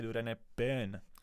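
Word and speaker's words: Doriane Pin: A jezdí jako rychle, mě se Dorian Pin. – Pozor, tady bych tě opravil jenom, už jsem dostal dvakrát upozornění, kterého samozřejmě vážím, na výslovnost téhle závodnice francouzské, Dorian Pin.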